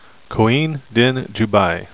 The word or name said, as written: coine